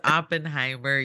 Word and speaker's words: Oppenheimer: It's not Oppenheimer. It's Oppenheimer. You know that.